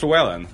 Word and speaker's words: Llewellyn: So when you have a double L in Welsh, sometimes you have Yeah, so it's Llewellyn. That's how you pronounce it in Welsh, or Cloyd. Cloyd is the is a Welsh name that's pronounced pronounced with that sound.